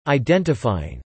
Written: identifying

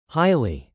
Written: highly

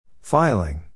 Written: filing

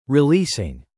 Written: releasing

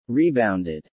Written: rebounded

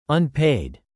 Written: unpaid

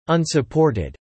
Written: unsupported

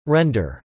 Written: Render

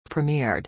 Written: premiered

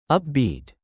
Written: upbeat

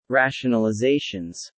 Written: rationalizations

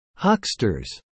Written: hucksters

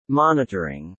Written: monitoring

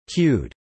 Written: queued

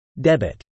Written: debit

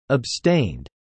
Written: abstained

